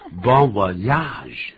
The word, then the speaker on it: Bon voyage: Bon voyage. Bon voyage. Goodbye. Goodbye. – Bon voyage. Voyage. – You did it. – 别忘了不是 ya. Bon voyage. – 对、这是一个外来语，所以跟我们想象的发音是不太一样的。